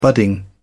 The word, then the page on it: budding: British English